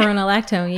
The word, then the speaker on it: spironolactone: And the other thing that was really incredible is that I had been on this medication called spironolactone. I'm not sure if I'm saying it right. – Yeah, spironolactone, Okay.